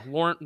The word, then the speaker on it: Laurent: Is it Laurent? Laurent Robinson? – Yeah, Laurent Robinson.